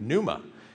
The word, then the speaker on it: pneuma: It's the same word where we get like words like pneumonia or pneumatic. I always miss these words on spelling tests as a kid because I'm terrible at spelling. And when you put a silent P on something, I'm just not going to sound that out, right? So this is where, I mean, it goes all the way back to the Greek, pneuma.